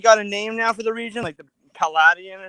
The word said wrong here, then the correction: Paldea: or something like that, right? – I think it's pronounced Paldea.